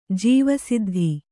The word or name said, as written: ಜೀವಸಿದ್ಧಿ